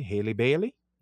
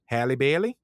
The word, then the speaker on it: Halle Bailey: For y'all who are mad at, I'm saying it right, Halle Bailey. Yes. – Mm-hmm. – Halle Bailey.